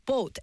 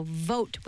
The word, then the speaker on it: vote: Because he was like mispronouncing the V and the B. So remember, it's the same sound as boat, like bay, 네. but with a V. So vote.